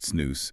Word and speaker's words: snus: So SNUS, and it's pronounced SNUS, is basically a moist, smokeless tobacco product.